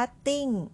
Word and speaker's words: 订: ติ้ง